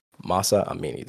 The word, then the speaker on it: Mahsa Amini: This podcast, this all stimulated off of Mahsa Amini.